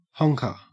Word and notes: honker: Australian English

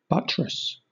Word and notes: buttress: British English

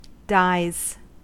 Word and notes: dies: American English

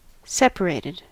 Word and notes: separated: American English

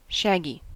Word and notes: shaggy: American English

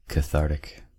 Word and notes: cathartic: American English

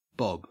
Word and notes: bob: Australian English

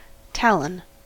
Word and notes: talon: American English

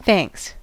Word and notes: thanks: American English

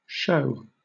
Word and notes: shew: British English

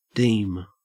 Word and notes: deem: Australian English

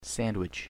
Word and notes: sandwich: American English

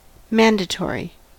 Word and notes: mandatory: American English

